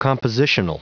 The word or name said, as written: compositional